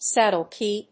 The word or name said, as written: saddle key